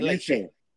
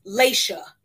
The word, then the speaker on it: Laysha: Because I feel like the first time she corrected us, she said Laysha. – She did say Laysha. – So I know her actual name, but the name that's up there, it's pronounced Laysha.